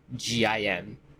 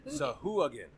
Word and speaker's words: Sahuagin: It's Sahuagin. There's a button! – Sahuagin. – Sahagin. – There's a button. I can press, pronounce and it'll pronounce it. Probably just played into the podcast because it had it on. I do it again. – Sahuagin. – Sahuagin. – Sahuagin. Okay. Sahuagin.